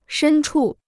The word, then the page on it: 深处: shēn chù